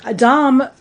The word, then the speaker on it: domme: So D-O-M versus D-O-M-M-E. And it's both pronounced dom. – Mm-hmm. Mm-hmm. Okay. – Both are pronounced the same.